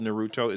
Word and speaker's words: Naruto: The only reason I even know it's called Naruto is because my son corrected me when I called it Nataro, because I've never watched it, and apparently I can't read. But I've been saying it wrong my entire life.